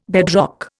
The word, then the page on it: bedrock: Indian